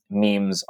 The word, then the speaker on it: meme: And essentially it's, I'll say this, if we have genes, which are akin to physical, memes are the cultural building blocks. And I'm just going to quote here. All right, quote, "The meme, which rhymes with beam, is the basic building block of culture in the same way the gene is the basic building block of life.